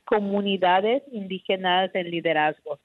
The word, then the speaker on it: Comunidades Indígenas en Liderazgo: Uh, uh, um, the name of the organization is Comunidades Indígenas en Liderazgo Cielo.